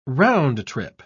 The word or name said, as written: round-trip